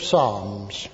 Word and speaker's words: Psalms: I want you to turn with me to the book of Psalms. If Mr. Paisley was here, I think he'd pronounce Psalms a little differently. However, I have to tell him that I'm pronouncing it correctly.